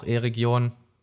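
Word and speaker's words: Eregion: wo dann auch E-Region. Ich habe es gelernt, habt ihr, habt ihr mitbekommen? Ich habe es richtig gesagt, glaube ich, und auch ohne Ohne Zusatz.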